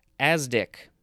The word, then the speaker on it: asdic: Next we have asdic, A-S-D-I-C. That's how it's pronounced.